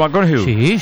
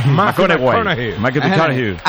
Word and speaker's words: McConaughey: Mateo McConaughey. – Sí. – Un futbolero, le gusta. Hay que ir corrigiendo la pronunciación. – McConaughey.